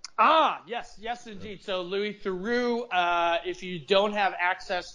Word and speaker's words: Theroux: His father is Paul Theroux, and you pronounce it Theroux, like true.